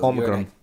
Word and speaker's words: Omicron: What's the correct way to say? Because it's a Greek word. – Omicron.